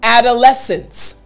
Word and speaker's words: adolescence: That's the main sound I want to focus on for this word. So again, after me to pronounce the word like a native English speaker. Adolescence.